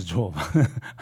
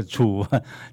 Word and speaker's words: 醋饭: 饭就会酸嘛，酸啊发酸啊，就是叫做即嘛，咱咧讲叫做错饭啊，错饭，加醋的醋啦，吼、哦，错饭啊，醋饭啊，不是错饭，读作醋饭，加醋，华语读作醋，哎，那么。